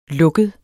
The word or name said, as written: lukket